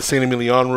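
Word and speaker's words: Saint-Émilion: I mean, I've done like a cab collective thing here in a big old room in like the San Emilion rooms or and stuff like that. So you... – We do, and thank you for pronouncing that special because I had a little trouble saying St. Emilion.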